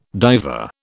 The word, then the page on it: diver: British English